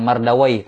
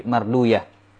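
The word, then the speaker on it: Mardawayh: Bukan mardawaih, tapi marduyah.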